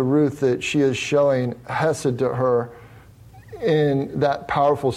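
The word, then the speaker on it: chesed (חסד): The phrase translated steadfast love comes from a very rich Hebrew term pronounced chesed.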